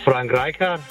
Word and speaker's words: Rijkaard: Det må have været Frank Rijkaard. – Messi! Der er ikke bonuspoint for udtale, men det var rigtig flot, det der.